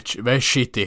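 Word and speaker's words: Večiti: Och det uttalas typ Vechiti, Vechiti Derby. V-E-C-I-T-I.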